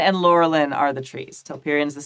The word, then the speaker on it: Laurelin: lorian the golden tree what, what I named my kid. Mm-hmm. so Laurelin. Oh, sorry. Yeah, Laurelin. I'm sorry. I've been saying it wrong this whole time.